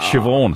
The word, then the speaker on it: Siobhan: Let's go to Murray Bridge. G'day, Cyban. – Siobhan. – Siobhan.